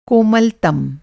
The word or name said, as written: कोमलतम